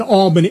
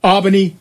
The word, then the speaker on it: Albany: It's there is no L in Albany. In Albany, and there is no first A in Manhattan.